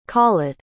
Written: callet

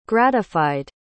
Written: gratified

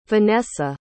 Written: vanessa

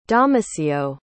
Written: domicio

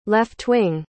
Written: left-wing

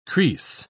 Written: kris

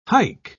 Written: haik

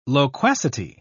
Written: loquacity

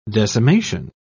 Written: decimation

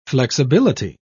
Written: flexibility

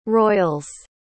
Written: royals

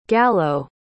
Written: gallow

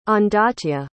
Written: ondaatje

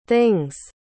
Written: thing's

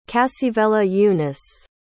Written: cassivellaunus

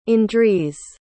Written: Andries

